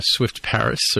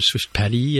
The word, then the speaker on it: Swift Paris: So we heard from our friend Marin Tordov, you know, sometimes host of this show, and he mentioned, I mentioned him appearing on Swift Paris, or Swift Paris, as it should be pronounced, but he was telling me that it's actually a meetup.